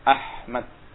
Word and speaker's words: Ahmad: Makanya kalau sebagian orang nulisnya dengan Ahmad dengan ACH atau AKH, salah ini tulisannya. Ya. Ahmad nanti bacanya. Ya benar apa? Pakai ha. Ahmad. Ah, bersih. Ahmad.